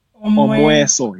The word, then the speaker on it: Omweso: And then among the Baganda people, it's called Omweso. – Omweso, yes. – Omweso, okay.